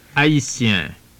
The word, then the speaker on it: Haïtien: The word, for example, for a Haitian man is Haïtien, Haïtien.